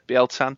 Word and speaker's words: Bieltan: Am I saying that right? You're a 40K guy. Does that sound right? – Yeah, it sounds right. – Yeah, Bieltan.